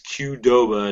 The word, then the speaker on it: Qdoba: And I checked to see how that was pronounced, and it really literally is Qdoba, and it's spelled Q-D-O-B-A, which doesn't seem that complicated.